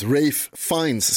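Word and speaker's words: Ralph Fiennes: stavas Fiennes, men jag tror man säger Fains. – Ray Fine. – Ja, precis. Rafe Fines ska det uttalas, trots att det inte stavas ens i närheten på det sättet.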